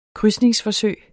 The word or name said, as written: krydses